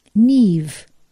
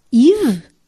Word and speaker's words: Niamh: Niamh. – Eve? Não, Niamh. É irlandês, respondo. Como é que se escreve? N-I-A.